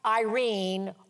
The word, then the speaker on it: eirene: But in Greek, the word for peace is spelled E-I-R-E-N-E, but it's pronounced Irene or Irene, like the name Irene.